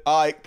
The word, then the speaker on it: Icke: David Icke pronounced pr- pronounce it ick, David Ick.